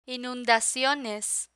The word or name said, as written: inundaciones